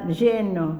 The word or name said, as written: lleno